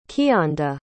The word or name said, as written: kianda